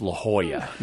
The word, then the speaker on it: La Jolla: Well, anyone who's wondering, it's La Jolla, not La Jolla. – It looks like La Jolla.